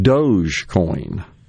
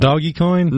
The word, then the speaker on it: Dogecoin: This one, you see this mispronounced all the time, Dogecoin. – It's not doggy coin? – Nope. Or Doja. How about this one? Eilish. The singer Billie Eilish. E-I-L-I-S-H.